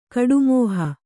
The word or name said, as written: ಕಡುಮೋಹ